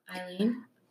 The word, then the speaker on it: Allene: Aline? – No, Aline. A-L-L-E-N-E.